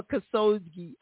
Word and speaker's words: Khashoggi: Khashoggi or Khashoggi.